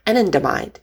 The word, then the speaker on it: anandamide: Anandamide, Uh, it comes, you know, from Ananda, some Indian Sanskrit ancient word that um, is translated pleasure, joy, delight. Um, anandamide.